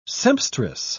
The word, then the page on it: sempstress: British English